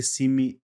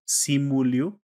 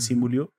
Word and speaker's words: Simu Liu: Y pues bueno, este personaje llega a la pantalla grande por fin en 2021, precisamente de la mano de, del actor chino-canadiense Simi, Simu Liu, espero que le pronunció bien, Simu Liu, que es quien precisamente trae la vida a Sang-Chi.